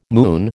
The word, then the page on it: moon: American English